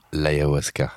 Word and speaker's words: ayahuasca: L'ayahuasca.